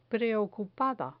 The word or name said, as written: preocupada